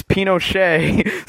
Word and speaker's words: Pinochet: And somebody will be like, "Well, it was Pinochet," and I'm like, "It's Pinochet."